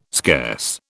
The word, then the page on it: scarce: British English